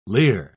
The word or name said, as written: leer